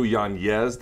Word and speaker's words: Yanez: His name actually is pronounced, this is true, Yanez. The N has an N-Y, but if we want to say Yanez, it's fine.